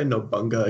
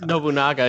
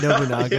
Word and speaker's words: Nobunaga: If you ever say Nobunga again, I'm going to cut you. – Did I say it incorrectly? I apologize. – It's Nobunaga, isn't it? – Nobunaga. Yeah. I'm so sorry.